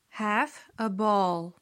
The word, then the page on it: have a ball: slow